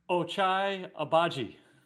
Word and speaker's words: Ochai Agbaji: Ochai Agbaji.